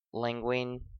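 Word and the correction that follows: Lugene: Lejeune. – Lejeune Chocolate Milk Stout.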